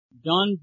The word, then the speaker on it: Don: This is Don Bradford. – Would you spell that for me, please? – Don Bradford. D-O-N-B-R-A-D-F-O-R-D. – D-O-N-B-R-A-D-F-O-R-D. O-R-D.